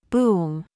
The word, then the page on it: BOB: American English